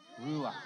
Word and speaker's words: ruach: Can you guys pronounce that? Ruach. You have to add at the end a ruach.